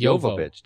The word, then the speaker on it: Jovovich: I actually looked it up because I was like, "How do you actually pronounce her name?" It's it's, it's Jovovich. Did Jovovich.